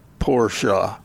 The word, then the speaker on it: Porsche: One of our listeners has posted how the correct way to pronounce Porsche, having looked it up online, and the correct pronunciation is, in fact, two syllables, Porsche.